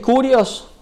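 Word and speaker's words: kurios (κύριος): Primero, la palabra Señor en el griego, que es kurios, o se escribe kyrios y lo vamos a transliterar,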